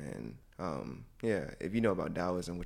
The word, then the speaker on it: Taoism: It's, well, mainly people spell it T-A-O, but it's pronounced Taoism, and, you know, it's created by Lao Tzu, L-A-O-T-Z-U, and it just preaches on the concept of the Force, which is energy, life force energy.